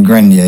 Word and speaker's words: Grenier: Famed archaeologist Robert Grenier, who discovered the San Juan in Labrador, dead at 88. Now, I pronounced his name Grenier, it's spelled G-R-E-N-I-E-R.